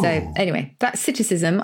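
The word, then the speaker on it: psittacism: Citticism, but it's got a silent P. – Oh, the P is silent, as in swimming pool.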